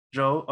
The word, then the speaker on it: Zhou: Because I counted Bottas with 6, and I think Zhou. Okay. – Yeah. – I'm relearning to say his name because Fred didn't correct me. whole time with me saying Zhao. I thought his name was Zhao, and then he never corrected me.